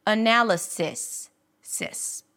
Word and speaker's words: analysis: But you can or should pronounce it with a relaxed E sound, analysis, sis.